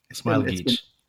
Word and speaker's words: Smailagić: Am I saying that right? – Smailagić.